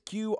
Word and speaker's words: Qrion: It looks like Cryin. – It could be Cryin. It could be Q Ryan. It's Qrion. Look her up on Spotify, because she's got a very good album, but this is definitely the highlight.